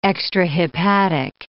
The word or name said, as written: extrahepatic